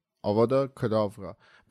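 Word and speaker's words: Avada Kedavra: آواده کداورا دقیقا تلفظ درست این هستش یعنی آوادا کداورا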